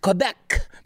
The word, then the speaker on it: Quebec: In the province of Quebec, pronounced Quebec,